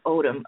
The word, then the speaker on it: Odom: Odom.